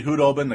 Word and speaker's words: Khudobin: It is officially Khudobin. The K is silent.